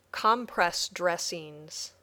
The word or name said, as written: compress dressings